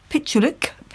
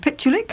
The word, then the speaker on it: Pichulik: Depends where you're saying it from. If you're in Germany, it's Pichulik. If you're in South Africa, yes, Pichulik will do just fine.